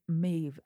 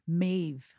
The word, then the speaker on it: Maeve: And we have a rating from Maeve. I don't know how to pronounce this name. – Maeve. – Maeve. – Yeah. – I always feel weird saying the E at the end. Maeve.